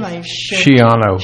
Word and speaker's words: Schiano: Schiano.